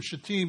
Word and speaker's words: Shittim: But when he sends the two spies from Shittim, you got to make sure you pronounce that word correctly. So if you put the emphasis and the accent on the last syllable and make it sound Hebrew like, no one gets kind of squirrely and giggling in the background. But if you put the accent on the first syllable, it gets kind of embarrassing, especially with the women who are around.